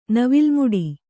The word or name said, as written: ನವಿಲ್ಮುಡಿ